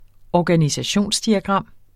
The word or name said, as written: organisationsdiagram